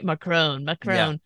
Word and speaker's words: Macron: that he knew secrets about Macron's love life. Am I saying that right? Macron.